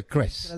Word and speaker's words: Krebs: El doctor Klaus Krebs, que se escribe K-R-E como una B, ¿no, Juan Pablo? Pero se pronuncia Krebs.